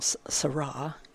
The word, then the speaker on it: Sarah: Cardinal Seurat, it's spelled like Sarah, but it's Seurat.